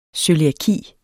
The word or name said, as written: cøliaki